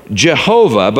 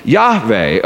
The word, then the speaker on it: Yahweh: Some used the vowels from the word Adonai, which means Lord, combined with the four consonants to produce Yehovah or Jehovah, but most scholars are now convinced that it should be pronounced Yahweh or Yahweh, Yahweh.